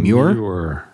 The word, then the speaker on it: Muir: Muir.